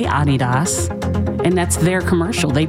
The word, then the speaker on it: Adidas: It's not pronounced Adidas. Mm-hmm. We're the only people in the world that pronounce it Adidas. – Okay. – Adidas is how to say it. Adidas. Because the guy who found it is a German guy named Adi Dassler. Mm-hmm. And he named it after himself.